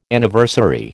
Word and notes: Anniversary: American English

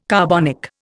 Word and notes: carbonic: Indian

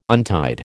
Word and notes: untied: American English

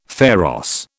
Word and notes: pharos: British English

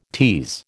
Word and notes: tease: American English

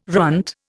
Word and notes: runt: Indian

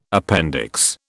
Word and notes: appendix: British English

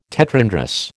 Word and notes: tetrandrous: American English

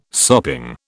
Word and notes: sopping: British English

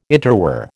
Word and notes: interwar: American English

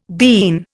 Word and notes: bean: Indian

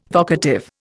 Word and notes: vocative: Indian

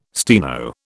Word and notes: steno: British English

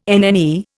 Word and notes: NNE: Indian

